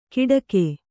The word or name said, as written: ಕಿಡಕೆ